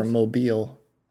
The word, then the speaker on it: Mobile: So the reason why they're called mobile homes is for Mobile, Alabama. – Do you know that's how you pronounce it? – Yeah, it's Mobile.